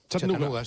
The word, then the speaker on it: Chattanooga: ¿Se pronuncia así en Chattanooga? – Chattanooga, sí.